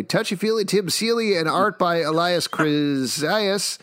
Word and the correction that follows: Kriazis: Kriazis, sorry, I actually know him, so I should probably pronounce his name correctly.